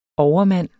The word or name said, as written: overmand